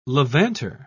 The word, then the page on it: levanter: British English